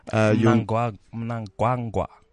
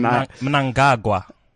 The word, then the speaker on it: Mnangagwa: And Mnangagwa. – Mnangagwa. Apparently they don't pronounce the N, apparently.